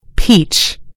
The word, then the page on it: peach: British English